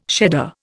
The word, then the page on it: shedder: Indian